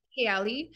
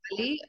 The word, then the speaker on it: Kaali: Both work. Yeah, I mean, I call it Kaali. In Arabic, it's Kaali, but I feel like that sounds a bit harsh, so I like to say Kaali.